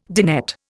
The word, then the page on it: dinette: Indian